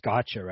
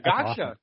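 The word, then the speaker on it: Gaxhja: I believe you said his name is pronounced Gotcha, right? – Yeah, you nailed that. Gotcha. – Awesome. Good, because that's a fantastic last name. It's G-A-X-H-J-A for those of you at home.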